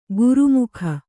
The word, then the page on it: ಗುರುಮುಖ: guru mukha